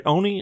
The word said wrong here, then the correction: Ori: and the Blind Forest, or is it Ori? – Ori and the Blind Forest. – There's an R in there.